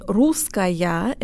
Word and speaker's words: русская: When your brain sees the feminine русская and the neuter русское written out, it will want to pronounce the endings differently. But in casual conversation, they sound practically identical, with de-emphasized schwa vowels and a very relaxed y sound. So you'll have to tell your brain to relax and go with the flow.